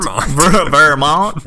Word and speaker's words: Vermont: Vermont. That's how the locals pronounce it, in Vermont.